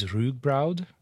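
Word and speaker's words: rúgbrauð: The traditional, like the Icelandic word for it is rugbraud. So right. – R-U-G-B-R-A-U-D, although some of those letters have accents that I don't understand.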